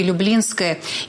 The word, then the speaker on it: Люблинское: Но вот для Люблинского кладбища есть. Оно все-таки Люблинское. И Артур Соломонов,